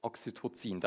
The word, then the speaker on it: Oxytocin: Und bei einer Geburt wird eben ein anderes Hormon ausgeschüttet und das heißt Oxytocin. War das richtig ausgesprochen? Oxytocin. Oxytocin, Dankeschön.